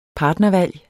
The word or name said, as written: partnervalg